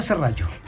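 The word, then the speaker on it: serrallo: Cerralo. – En el Cerrallo. – Entre Cerrallo y Cerralo, ¿ah? – Cerrallo. – Se pronuncia Cerrallo.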